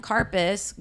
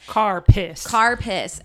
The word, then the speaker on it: Karpis: K-A-R-P-I-S. – Karpis. And I watched a couple videos because I was like, is it Karpi? Like French try pronunciation. Try to French it up a little bit. – Karpi. – Dierte. – It wasn't. It's Karpis.